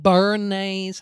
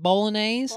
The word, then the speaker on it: bolognese: Listen, Megs is going to get a straight up bomb-ass bowl of bolognese. How do you say it? Bolognese? – Bolognese.